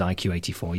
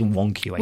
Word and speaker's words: 1Q84: Well, that's okay, because on the you remember the video, works, the video podcast for this, I've been pronouncing it as IQ84, yeah, but it is 1Q84.